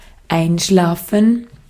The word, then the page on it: einschlafen: /ˈʔaɪ̯nʃlaːfɱ̩/; /ˈʔaɪ̯nʃlaːfən/